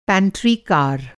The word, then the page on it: पैंट्री कार: Hindi